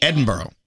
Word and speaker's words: Edinburgh: What's that city in Scotland that everyone mispronounces? – Edinburgh? – You said it correctly. – Oh, okay. – Edinburgh. But it's spelled Edinburgh.